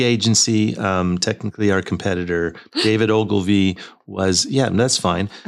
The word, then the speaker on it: Ogilvy: Ogilvy? O-G-I-L-V-Y. – Oh, Ogilvy.